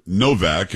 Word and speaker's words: Nowak: Now, again, it's spelled Nowak, but she says there they most probably pronounce it Novak, and she's from that area, so she would know better than I.